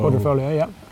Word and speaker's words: Quadrifoglio: Quadrifoglio. – Mm-hmm. You don't pronounce the G.